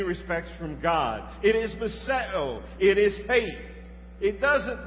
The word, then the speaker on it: miseo: It's a word that we pronounce miseo. Miseo. M-I-S-E-O is the transliteration, the way we write it.